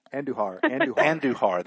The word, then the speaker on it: Andujar: How do you say his name? Adujar? Adujar? Never mind. If you don't know, forget it. – Oh. – We'll put it. We'll post it on Golf Talk America. Okay. We'll post it. – Andujar. – That's how you say it.